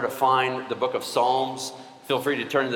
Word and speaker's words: Psalms: It's Psalms. It begins with a P. The P is silent.